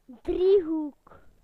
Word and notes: triangle: British English

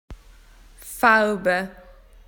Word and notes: colours: Australian English

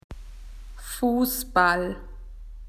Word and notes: to play football: Australian English

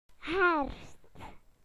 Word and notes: automn: British English

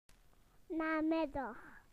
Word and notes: afternoon: British English

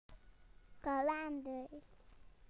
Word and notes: calendars: British English

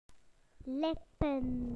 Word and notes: lips: British English

